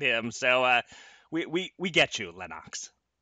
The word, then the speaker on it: Lennox: Lennox Lewis clarified that his name is pronounced Lennox, not Lennox, as he's let Americans apparently pronounce it wrong his whole career, his whole life.